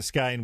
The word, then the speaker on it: Skehan: Patricia Skane, which is spelled Skehan, if you're interested in buying the book.